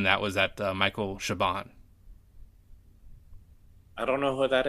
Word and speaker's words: Chabon: Chabon, Chabon.